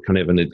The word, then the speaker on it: Cynefin: So just to start with Canevan, it's spelled CYNEFIN,